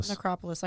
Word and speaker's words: Necropolis: And he basically goes down to Necropolis. – Necropolis. – I knew I was fucking saying that wrong.